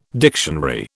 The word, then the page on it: dictionary: British English